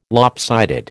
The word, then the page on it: lopsided: American English